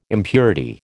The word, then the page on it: impurity: American English